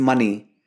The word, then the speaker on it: Mani: Well, it's money, written as M A N I, but pronounced as M O N E Y.